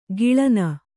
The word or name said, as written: ಕಳನಿ